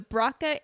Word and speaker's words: Broca: There's no Z whatever, the Braca and Wernicke area. The Braca area allows language to be produced. the, the, mine's not working. The Broca